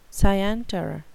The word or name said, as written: scienter